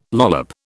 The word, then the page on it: lollop: British English